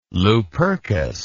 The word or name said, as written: lupercus